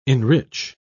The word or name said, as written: enrich